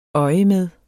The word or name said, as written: øjemed